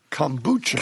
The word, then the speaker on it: kombucha: For some reason, we're talking about kombucha. – Kombucha. – Now. – You know, you pronounced it right. You got it right.